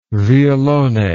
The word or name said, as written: violone